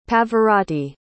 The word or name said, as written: Pavarotti